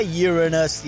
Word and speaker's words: Uranus: It's A, Uranus.